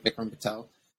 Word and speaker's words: Vikram Patel: Vikram Patel.